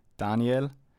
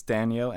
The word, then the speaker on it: Daniel: I think when I was younger, um, my parents always called me Daniel but you know you guys pronounce it different differently. In German, I would say Daniel, and as a nickname, Dani. But in Denmark and in the States, it's Daniel and Danny, and I definitely prefer Danny.